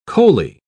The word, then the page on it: coaly: British English